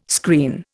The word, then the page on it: screen: Indian